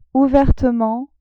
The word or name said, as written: ouvertement